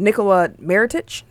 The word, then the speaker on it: Nikola Mirotic: Crazy Eyes and Nikola Mirotic. Did I say that right? – Nikola. – Close enough.